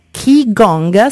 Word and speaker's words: Qigong: Sul corpo, e ti ispiri alle tecniche di Qigong, si pronuncia così? – Sì, esatto, ti sento comunque sempre molto lontana, comunque penso di avere capito, e intanto parlo così magari vi do modo di risolvere il problema.